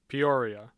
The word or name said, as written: peoria